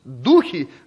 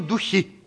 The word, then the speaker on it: духи: Никогда не говорите «духов изгнал». Духов, духи в бутылочке, а духи действуют в воздухе. И надо очень коряво звучит речь проповедника, который говорит духов или духами, духами. Но это просто-напросто даже не истолкование слова, а правильное произношение. Где-нибудь в песне, в псалме, да, там может быть значит, неправильное ударение поставлено. Но когда мы говорим о духовном мире, духи, а не духи.